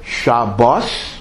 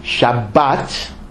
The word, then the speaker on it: Shabbat: But technically, you have to pronounce it either Shabbos, if you follow the almost extinct Ashkenazic pronunciation of Hebrew, but it's almost extinct, or Shabbat, and Torah also is Torah, not Torah, because in Hebrew most words are accented in the last syllable, not the first syllable.